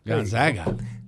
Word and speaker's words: Gonzaga: Gonzaga.